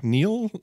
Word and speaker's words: Nihil: So Rivers of Nile, if I'm saying Nile right, because Nile is spelled N-I-H-I-L. – Okay, yeah, Nile. Like, yeah. Neil? Like, the only other pronunciation I would imagine would be Neil. – Yeah, or like nihilism. Or Nihil. Rivers of Nihil.